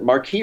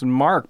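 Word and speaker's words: marque: Is it Marquis or Mark? – I think it might. I've always heard it pronounced Mark, but I think Marquis sounds a lot better. – Yeah. – Then I pronounced it incorrectly.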